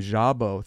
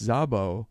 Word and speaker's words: Szabo: And I pronounced his name Szabo, thinking I was, cool or something. And they corrected me and said, no, it's Szabo. If it was Szabo, it would have begun with a Z.